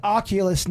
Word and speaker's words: Oculus: It's Oculus, not Noculus. I said it wrong, but you know what I'm talking about.